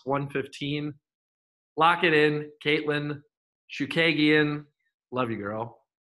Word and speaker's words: Chookagian: My first pick is Katlyn Chookagian. That's right, Chookagian.